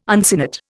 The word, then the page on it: uncinate: Indian